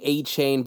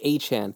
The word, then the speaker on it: Achane: I know everyone had been previously saying Achane, but apparently his team says it's pronounced Achane.